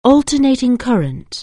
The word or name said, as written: alternating current